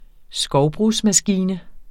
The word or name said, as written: skovbrugsmaskine